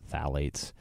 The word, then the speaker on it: phthalates: It's spelled P-H-T-A-T-H-A-L-A-T-E-S. So it's spelled kind of weird, but that's how they pronounce it, phthalates.